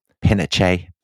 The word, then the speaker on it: Pinochet: But we respect Chilean pronunciation, yeah. So it will be General Pinochet.